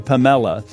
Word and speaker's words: Pamela: It's spelled Pamela, but she reminds me the accent's on the second syllable. So thank you, Pamela.